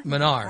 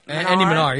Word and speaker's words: Manar: And Andy Manor? – Manar. – How do you say Andy Manar, yeah.